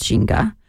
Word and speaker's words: Ginga: Jinga is G-I-N-G-A. A lot of people pronounce it as Ginga, but in Portuguese, it's Jinga.